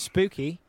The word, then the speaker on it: spooky: And spooky rhymes with Lukey, i.e. Gospel of Luke, lukewarm.